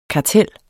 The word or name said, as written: karteller